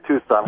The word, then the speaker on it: Tucson: I think that's Tucson there. – That is correct. It is Tucson. – You know why? It's spelled like the Tuscany region on my screen.